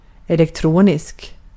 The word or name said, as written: electronic